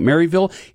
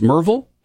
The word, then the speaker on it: Maryville: For those that just moved here, you're pronouncing it Maryville. It should be pronounced Merville if you've been here for your whole life.